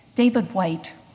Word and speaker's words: Whyte: David White, that's spelled W-H-Y-T-E.